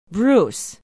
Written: bruce

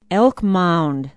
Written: elk mound

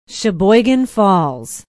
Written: Sheboygan Falls

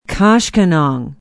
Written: koshkonong

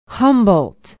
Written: humboldt

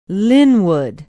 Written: linwood

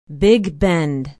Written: big bend

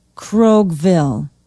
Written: kroghville

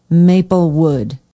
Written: maplewood

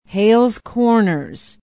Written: hales corners

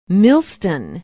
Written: Millston

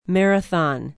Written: Marathon

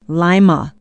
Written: lima

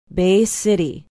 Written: bay city

